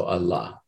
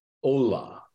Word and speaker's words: Allah: Now, in, uh, you know, almost everybody who works on the Sufis would, would uh, spell that a L L, long A H. Okay. And so Allah. But the problem is, is in Bengali you can't uh, you can't produce that sound. If I were to uh, transliterate the Bengali, it would be pronounced Ola. And I'm sorry, the the God of of Islam is not going to be, you know, understood very well as Allah, particularly given all the, you know, uh, in an English-speaking environment, what that invokes.